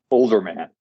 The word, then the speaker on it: Olderman: Olderman, just how it's spelled.